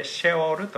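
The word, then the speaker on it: シ ェ オール (שְׁאוֹל): これが読みと言われるところ、ヘブライ語でシェオールと言います。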